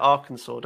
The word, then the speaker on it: Arkansas: It's, I know, but they pronounce it Arkansas, don't they? – Is that correct? – It is correct, yeah.